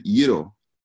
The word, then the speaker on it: gyro: So you pronounce it gyro, right? So at the G is a silent like Y, and then the S is silent as well.